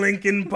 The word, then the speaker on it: Linkin: I was in Linkin Park. Not pronounced Lincoln like the president."